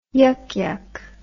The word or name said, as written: yuk yuk